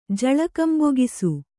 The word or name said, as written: ಜಳಕಂಬೊಗಿಸು